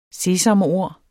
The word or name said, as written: sesamord